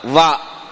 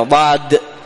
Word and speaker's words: ضاد: فبعضهم يقول للضاد ضاء، خطأ هذا، يجعل الاثنين ضاء، يقول حرف الضاء، لا، حرف الضاد لابد أن تنطق به هكذا ضاد،